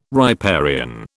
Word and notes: riparian: British English

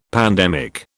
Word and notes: pandemic: British English

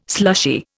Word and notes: slushy: Indian

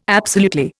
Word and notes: absolutely: Indian

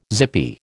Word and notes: zippy: American English

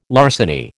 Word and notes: larceny: American English

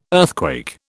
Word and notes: earthquake: British English

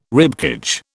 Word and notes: ribcage: British English

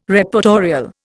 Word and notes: Reportorial: Indian

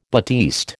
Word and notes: batiste: American English

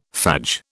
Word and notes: fadge: British English

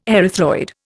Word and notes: erythroid: Indian

